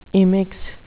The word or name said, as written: immix